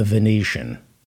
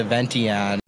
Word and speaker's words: Venetian: Notice the way this moron, this murderous moron, pronounces the Venetian. – Oak Room at the Ventian. – The Oak Room at the Ventian. – Oak Room at the Ventian. – Stupid, illiterate, gun-toting, murderous moron. It's pronounced the Venetian.